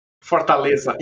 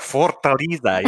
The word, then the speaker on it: Fortaleza: And I may be not pronouncing this right, but it's Fortaleza? – Fortaleza, yes. – I got to roll the R, Fortaleza.